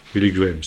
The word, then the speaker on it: Billy Graham: Billy Graham, si on veut employer la prononciation américaine.